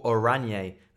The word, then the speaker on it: Oranje: He's a writer with Pinnacle and a YouTuber for Football Oranje. That's how you pronounce orange in Dutch.